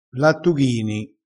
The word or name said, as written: lattughini